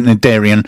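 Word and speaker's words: Cnidarian: Great work on pronouncing Nidarian. It is indeed a silent C.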